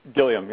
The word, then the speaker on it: Gilliam: Gilliam, yeah.